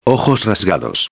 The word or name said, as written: rasgados